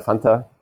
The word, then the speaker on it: Fanta: My name is Alexander Fanta, conveniently spelled like the drink.